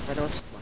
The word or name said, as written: broskua